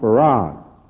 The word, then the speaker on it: Fard: who's called Farad. It's spelled F-A-R-D, but it's pronounced F-A-R-A-D, Farad.